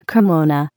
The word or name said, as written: kimono